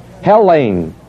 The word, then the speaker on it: Hellene: It's Hellene. H-E-L-L, long E-N. Hellene. Hellene. Long E-N.